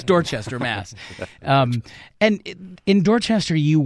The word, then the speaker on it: Dorchester: Is it Dorchester? – You can use every letter in that word.